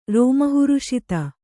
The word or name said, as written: ರೋಮಹರ್ಷಿತ